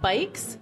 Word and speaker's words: BYCS: It's, you would pronounce it Bikes, mm-hmm. B-Y-C-S,